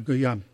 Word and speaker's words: Guillaume: Is it Guillaume? – Yeah, Guillaume.